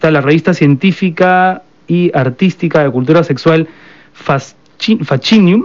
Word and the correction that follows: Fascinum: Este, en latín la pronunciación es fascinum.